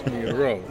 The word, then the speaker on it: Miró: Accent on the O, so it's Miró.